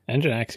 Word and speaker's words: Nginx: Nginx, you got it.